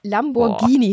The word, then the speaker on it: Lamborghini: Ja, weil Lamborghini hinter dem G ist ein H und im Italienischen spricht man das dann wie ein I aus.